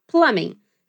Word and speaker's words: plumbing: Essa palavra significa encanamento, se escreve plumbing. Mas a gente não pronuncia esse B, então ele fica só plumbing.